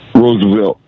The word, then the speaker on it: Roosevelt: Am I saying that right? – Roosevelt. – Oh, Roosevelt. Okay, it's missing a letter.